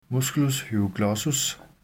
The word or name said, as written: m. hyoglossus